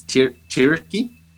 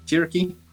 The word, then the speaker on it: Tiryaki: They were doing a short scouting mission down the Tiryak. No, that's not how you pronounce it. It's Tiryaki. Tiryaki.